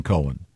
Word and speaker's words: Cohen: No, not when it's spelled C-O-H-E-N. – It's definitely Cohen.